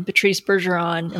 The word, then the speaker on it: Patrice Bergeron: I'm not a hockey fan, but I thought, even I think I've heard of that name when she said Patrice Bergeron. Am I saying that right? – Yeah.